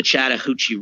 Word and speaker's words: Chattahoochee: into the Chattahoochee River.